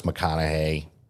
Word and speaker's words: McConaughey: And of course, McConaughey. Killed it. Did I say that right? – Chilled it. – So I fucked up and chilled it, but I actually said his name right.